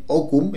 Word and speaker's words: Hokum: Se escribe H-O-K-U-M, Okum.